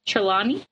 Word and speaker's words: Trelawney: Trelawney.